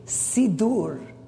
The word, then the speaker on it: Siddur: And you know how you say prayer book in Hebrew? Sidur. S-I-D-D-U-R. Sidur.